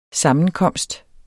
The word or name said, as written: sammenkomst